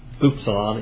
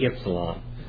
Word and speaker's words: Ypsilon: That should not be Upsilon. It should be Ypsilon. That's a Y. Yeah. That's a Y. That is not pronounced U. It is not an Upsilon, but an Ypsilon. A lot of the grammars, well, most of the grammars mispronounce this word. Okay? It's Ypsilon.